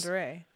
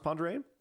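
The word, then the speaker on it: Pend Oreille: He lived on Ponderay, which is, and I can't pronounce the lake very well. Penn-Oriel. It's in northern Idaho. It's the lake that's Ponderay. – Is it pronounced Ponderay? Mm-hmm.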